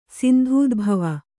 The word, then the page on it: ಸಿಂಧೂದ್ಭವ: kannada